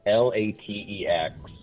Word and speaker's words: LaTeX: It's spelled LaTeX, LaTeX. But it's pronounced LaTeX, and it's stylized as capital L, lowercase it.